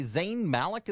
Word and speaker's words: Zayn Malik: Zayn Malik is turning 25. – Hey. – Yep. – Did I pronounce that right? – You did. Good job.